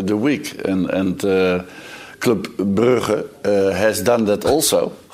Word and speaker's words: Club Brugge: Bare for uh, å ha Vi har diskutert, vi diskutert Klubb Brygge i dag, ja. men vi har jo alle gjort det uten å, å, uttale det riktig. Vil vi ha riktig uttale fra Louis van Gaal?